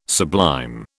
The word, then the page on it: sublime: British English